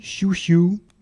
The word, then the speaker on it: Xiu Xiu: Donc, je vais vous passer Chiu Chiu. Ça s'écrit Xiu Xiu, hein, mais c'est à la chinoise.